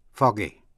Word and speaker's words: foggy: Một chữ nữa để ý là chữ fog, foggy, là có sương mù, thì G vẫn đọc là G, vì muốn giữ âm G ở cuối chữ fog. Fog, foggy. Phát âm là G, những chữ có âm tiết là G-E hay là G-Y.